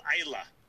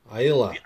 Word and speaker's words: Islay: But I tell you, instead of like the Islay, Islay region, it's called Islay. – Islay.